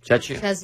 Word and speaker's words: Czechia: Czechia.